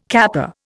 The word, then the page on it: kappa: Indian